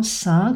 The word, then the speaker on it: seing: Petite exception intéressante dans la langue française, le blanc seing, donc qui s'écrit E-I-N-G, on ne prononce ni le N ni le G. Un blanc seing qui est une signature que l'on appose à l'avance sur un document en blanc.